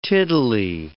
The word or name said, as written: tiddly